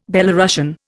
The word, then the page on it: Belorussian: Indian